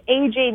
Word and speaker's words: Ajaye: So the best way is, so my website is AJ Music,